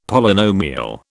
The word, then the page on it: Polynomial: British English